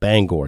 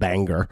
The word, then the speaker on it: Bangor: Bangor, oh my goodness, I am from Maine. Pronounce it right, Matt. Bangor, it's not Bangor.